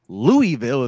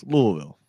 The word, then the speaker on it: Louisville: Or if you're in Kentucky, it's not Louisville. It's Louisville.